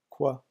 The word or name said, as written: quoi